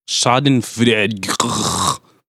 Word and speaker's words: Schadenfreude: It's like a guilty Schadenfreude or Schadenfreude. I don't know how you pronounce it exactly. – Schadenfreude. – Thank you, our resident German expert.